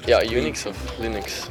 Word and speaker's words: Linux: As Linux.